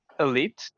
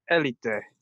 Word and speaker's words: Elite: Um, een daarvan is de Elite, dat is in het Engels uitgesproken natuurlijk, maar in het Spaans is Elite, met accentje op de E.